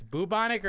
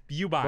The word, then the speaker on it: bubonic: It's bubonic or it's bubonic? – Both are acceptable. – Both are acceptable. – Bubonic is like in Britain.